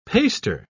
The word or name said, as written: paster